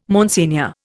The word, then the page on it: Monsignor: Indian